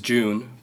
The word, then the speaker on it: June: My first name is June, pronounced like the month June.